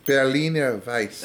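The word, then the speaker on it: Berliner Weisse: Berliner Weiss.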